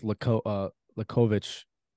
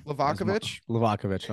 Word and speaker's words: Livakovic: I went with Lukovic. Croatian goalkeeper. – Livakovic.